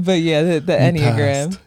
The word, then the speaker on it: Enneagram: The one that I'm talking about is called the Enneagram. It sounds weird. It's spelled Enneagram. If you want to look it up. – It's Enneagram.